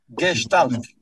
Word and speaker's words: Gestalt: Chiedo scusa subito della pronuncia del mm, psicologo della gesta, Gestalt, come... Gestalt. Gestalt, ok.